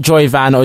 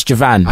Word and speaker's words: Joivan: At Jovan Wade, that's J-O-I-V-A-N. By the way, the way you pronounce my name, it's not Joyvan or Javine or Javane or whatever you want to call me. It's Jovan.